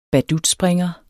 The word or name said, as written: BADUTSPRINGERES